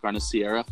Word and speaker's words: carne asada: Carne asada.